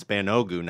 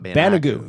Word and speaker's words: Banogu: It's Banogu, not Banigou.